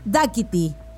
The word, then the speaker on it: Dakiti: Así es, lo dije bien, Dakiti.